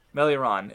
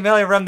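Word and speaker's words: Meleoron: but, Melioron, I've been saying Meliorem the entire, I think you even said it correctly, but you just never corrected me.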